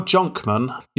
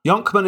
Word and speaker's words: Jonkman: Yonkman, yeah, or Jonkman. Yonkman is how I pronounce it, but again, this is America and they pronounce things differently over there, so it could be Jonkman.